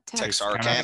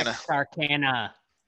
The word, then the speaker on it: Texarkana: Texarkana. – Texarkana.